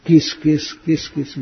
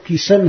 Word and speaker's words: कृष्ण: कई लोग ऐसे करते हैं, किस किस किस किस भी कर देते हैं। इसलिए पंजाबी लोग तो किशन ही कहते हैं, किशन, कृष्ण नहीं कहते।